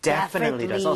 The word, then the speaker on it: definitely: 자, 그런데 강세를 여러분들 이쪽에 줘보는 거예요. 이렇게 하게 되면 definitely 이렇게 앞쪽에 강세가 가면서 뒤에 틀리라고 할 여유가 안 생기게 돼요. – 네. – 그렇게 돼서 definitely 이런 이상한 발음이 아니라 definitely 이렇게 발음이 될 수밖에 없습니다.